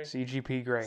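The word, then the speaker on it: CGP Grey: Did I get the name right? – Yes. No, you did not get the name right. – It's CGP Gray? – CGP Gray. – CGP Gray. Okay.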